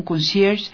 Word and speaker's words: concierge: Pues este ecuatoriano recibió el más grande reconocimiento para un concierge dentro de la industria hotelera global. Me dicen que así es que se pronuncia conserje en francés, y yo pues muy obediente lo digo.